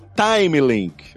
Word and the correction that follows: Timely: Eu tô pronunciando correto, Marcelo? É Time Link? É, Timely, Timely. – Timely. – Timely, é. De Timely Comics, que era o nome original da Marvel e tal, tem esse lance.